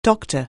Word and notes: doctor: anglais

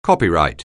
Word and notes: copyright: anglais

